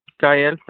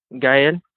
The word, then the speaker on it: Gael: My name is Gael Salcedo. – What is it? What's your first name? – Gael. – How do you spell that? – Gael.